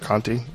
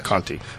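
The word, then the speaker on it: Conte: C-O-N-T-E. I'm saying it right. Conti? – Conti, yes. – Conti.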